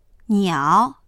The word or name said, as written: niao3